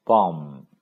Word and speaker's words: bomb: b arm b a b bomb 字母 b 也不发音。